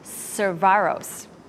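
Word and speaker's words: Cavazos: A ceremony will take place in May to rename the Army base after General Richard Edward Caravas, pronounced Cervaros.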